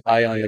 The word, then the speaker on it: Ayyan: In like Ayyan, you know, that's my name, but I tell people to pronounce my name as I-O-N, Ayyan, like science, you know, the element, you know, right?